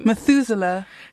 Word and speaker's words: Methuselah: So, Methuselah, the oldest man in the Bible, died at what age? How do you say his name? Methuselah.